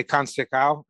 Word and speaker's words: Canseco: I heard him say Canseco.